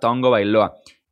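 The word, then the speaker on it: Tagovailoa: la pronunciación correcta es esa, Ton Gobailoa. Se podrá escribir Tagobailoa, pero se pronuncia Tongo Bailoa.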